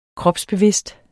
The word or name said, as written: kropsbevidst